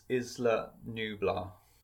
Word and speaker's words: Isla Nublar: It's is Isla Nublar.